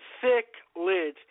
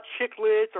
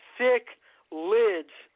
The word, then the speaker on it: cichlids: And by the way, beginners, it is pronounced cichlids, not chicklids or chicklets or anything. It's cichlids. It's pronounced like sick, and as in S-I-C-K, lid, as in L-I-D, cichlid. So I thought that would be great information for you guys to recognize. It's pronounced cichlid.